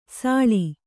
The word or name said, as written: ಸಳಿ